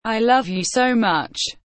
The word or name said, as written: i love you so much